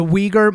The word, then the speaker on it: Uyghur: Many of you have probably seen the name of these, the Uyghur Muslims is how I've heard it pronounced in multiple different places. It's spelled U-I-G-H-U-R-S or U-Y-G-H-U-R-S.